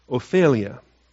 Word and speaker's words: Ophelia: All right, Ophelia, I guess, if I want to give it the best pronunciation there. Like my daughter, Alethea, it's got that ea ending. Ophelia.